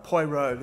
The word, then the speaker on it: Poirot: Poirot, is that how you say his name? – Poirot, there you go.